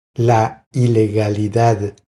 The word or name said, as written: La ilegalidad